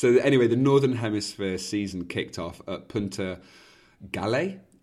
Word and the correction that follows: Punta Galea: Punta Galia in northern Spain.